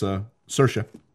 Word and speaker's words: Saoirse: So it's Saoirse.